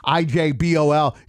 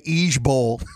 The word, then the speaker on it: IJBOL: Instead of saying IJBOL, you're supposed to say Ejbol.